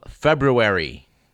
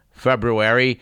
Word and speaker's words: February: At any rate, our date in question today is the 7th of February. And to cut Mr. Merlin off before he asks me, I would emphasize that there are, in fact, two R's in February, and they are both pronounced.